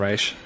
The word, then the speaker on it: Ra's: Yeah, they say it like two different ways through the entirety of RRA. They like switch between Ra's and Ra's. – It's actually Ra's.